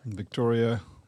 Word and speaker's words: Victoria: Victoria. The O is silent. Bouloubasis. – So it's Victria. – Yeah. Yeah. Let's go with that.